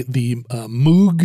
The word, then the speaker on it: Moog: The Moog synthesizer. His name is Bob Moog, even though it's spelled M-O-O-G.